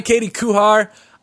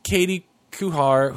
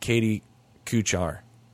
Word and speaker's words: Katie Kuchar: And I think I've been saying her name wrong. I think I've been pronouncing it Katie Kuchar.